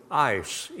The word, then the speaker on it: eis: But even if you want to just go with the word believe or faith, the Greek term is pistuin, eis, E-I-S, pronounced like I-C-E.